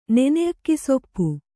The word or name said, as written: ನೆನೆಅಕ್ಕಿಸೊಪ್ಪು